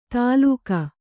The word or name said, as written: ತಾಲುಕ